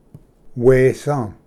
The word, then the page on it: ᏪᏌ: wesa